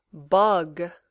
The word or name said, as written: bug